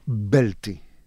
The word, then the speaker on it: belti: Mela, għanna, per eżempju, kelma belti, bel-ti, fuq l-ewwel sillaba, tiġi jiġi l-aċċent primarju.